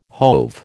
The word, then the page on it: hove: American English